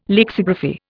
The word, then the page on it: lexigraphy: Indian